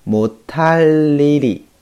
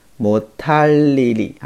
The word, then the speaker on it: 못할 일이: 면 就是只要你下决心的话啊，只要你呃决定了的话，怎么样 못 할 일 이 없 어 요 啊， 못 하 다 呢，它是不能的意思啊，不会的意思啊。 못 할 일 이 없 어 요 啊，本来是这样的啊，但是呢，因为这个地方会有一个音变，一个添加音啊，所以读的时候呢，最好读成 못 할 일 이 啊， 못 할 일 이 啊， 못 할 일 이없 어 요 啊， 못 할 일 이없 어 요，所以连起来就是 마 음 만 먹 으 면 못 할 일 이없 어 요。